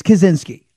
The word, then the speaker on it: Kaczynski: The guy that, see, Andrew Kaczynski, it's Kaczynski. I believe it's Kaczynski.